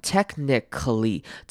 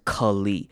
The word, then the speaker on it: technically: Technically 这个字要特别注意的是它最尾巴的念法， technically 这边虽然它是写 C-A-L-L-Y，但是这边的 A 我们要念成轻音，可能会念成 cli，或者是直接省略念成 cli 也可以。